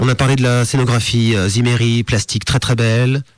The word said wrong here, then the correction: Zimmerly: Zimmery? – Zimmerly, pardon, j'écorche son nom.